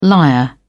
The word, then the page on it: lyre: British English